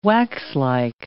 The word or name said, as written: waxlike